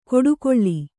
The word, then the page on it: ಕೊಡುಕೊಳ್ಳಿ: koḍukoḷḷi